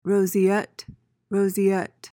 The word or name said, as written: roseate